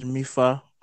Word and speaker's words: Mifa: And he's none other than, he said the pronunciation of his name is Mifa. So he's none other than Mifa Adejumo. And he introduced yourself, Mifa. – Okay, man. The pronunciation of my name is Mifa.